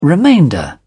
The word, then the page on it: remainder: British English